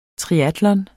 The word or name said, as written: triatlon